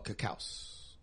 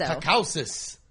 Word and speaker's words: Caucasus: Oh, Caucasus. – Yes. – Caucasus? I think that just means the land of white people. – I think so. – Caucasus.